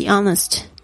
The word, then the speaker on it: honest: Be honest. Honest 就是诚实为人，大家注意一下这个 honest 那个 H 没有发音。